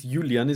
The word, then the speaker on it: Julian: So Julian, I'm guessing it's Julian, isn't it? Because it's Julian Brandt. So this is Julian Draxler.